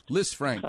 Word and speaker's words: Lisfranc: Lisfranc.